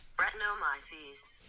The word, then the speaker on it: Brettanomyces: Brettanomyces.